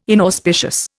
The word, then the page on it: inauspicious: Indian